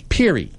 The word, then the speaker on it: Peri: It's going to be this coming weekend of Iolanthe and the Peer and Peri. They said that right? – Peary.